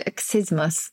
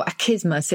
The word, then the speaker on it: accismus: Well, because this word, achismus, or achismus, if you, I think it, you can pronounce it either way probably now, A-C-C-I-S-M-U-S, and it's the insincere refusal of something you really want.